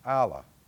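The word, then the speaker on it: Allah (ἀλλά): In the Greek, it's pronounced Allah.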